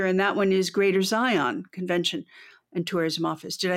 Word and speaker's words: Zion: Maybe a little less emphasis on the second half of the word. I think Zion is how most people will say it.